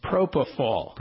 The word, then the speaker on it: propofol: What I heard, is that how you pronounce it? Propofol? How do you pronounce that? – Propofol. – Propofol, okay. – P-R-O-P-O-F-O-L.